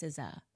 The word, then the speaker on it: SZA: Because it's spelled S-Z-A. I was like, Lizzo and SZA have me in their song. And she looked at me, and she was like, it's SZA.